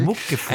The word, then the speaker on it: Muckefuck: Sollte man auch nicht Englisch aussprechen. – Muckefuck.